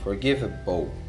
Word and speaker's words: forgivable: Um detalhe, sempre quando você tem esse BLE no final das palavras, ele vai ter a pronúncia de O, forgivable, ok? é a mesma pronúncia de mesa, table. Tem o BLO, BLE no final, table, forgivable. E a letra F você tem engaging, character and exceptional.